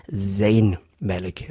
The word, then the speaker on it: Zayn Malik: Das heißt, richtig ausgesprochen, Zayn Malik. Ja. Zayn Malik. Das ist summende Z, wie es eigentlich nur im Englischen vorkommt.